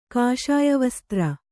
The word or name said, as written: ಕಾಷಾಯವಸ್ತ್ರ